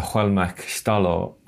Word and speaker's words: Xwélmexw: the Xwélmexw artist, curator and writer Dylan Robinson.